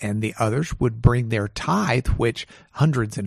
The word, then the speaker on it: Tythe: It's called the Tithe Barn. Now, they spell that T-Y-T-H-E. – Oh, like the tithe of to the church or something.